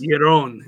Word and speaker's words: Yaron: How's it pronounced? Or how's it spelled? – Y-A-R-O-N. Weitzman. – Well, it's definitely a Jewish guy. – Yes. – Yaron. It's Yaron. It's probably an Israeli name.